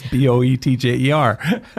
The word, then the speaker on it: Boetjer: So it's Boetjer. – But don't try to pronounce it from that. – Yeah, yeah. It's a whole different ballgame.